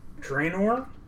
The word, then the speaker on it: Draenor: The new World of Warcraft expansion, Warlords of Draenor.